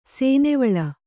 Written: ಸೇನೆವಳ್ಳ

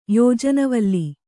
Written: ಯೋಜನವಲ್ಲಿ

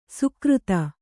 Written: ಸುಕ್ರುತ

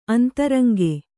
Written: ಅಂತರಂಗೆ

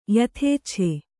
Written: ಯಥೇಚ್ಛ

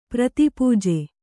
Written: ಪ್ರತಿಪೂಜೆ

